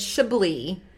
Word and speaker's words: Chablis: It's Chablis. The S is silent.